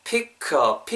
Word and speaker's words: pick a: k랑 a date 할 때 관사 어랑 붙으면서 pick a, pick a date 이렇게 하지 마시고 pick a, 피커, 피커, 피커, 피커. K 사운드랑 어랑 붙으면서 피커 이렇게 음이 연결되겠죠? 그래서 그거 챙기면서 피커 데이트, 피커 데이트. pick a date 이렇게 발음하시면 좋을 것 같아요. pick a date 안 돼요. 그죠? pick a date 그러면은 K 사운드가 뭉개져서 pick a 약간 G로 느끼지 않을까요? 그래서 pick a, pick a date 이렇게 발음하시면 될 것 같습니다.